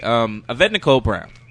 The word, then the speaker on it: Yvette Nicole Brown: Yvette Nicole Brown.